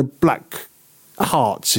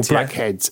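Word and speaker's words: Blackhearts: is it Joan Jett and the Black Hearts, is it? – That's correct. – Or blackheads.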